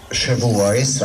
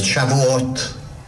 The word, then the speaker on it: Shavuot: A mi hagyomány világunk pedig úgy ismeri, hogy Shavuot, ahogy mi kiejtjük, vagy Shavuot, ahogy Izraelbe kiejtik.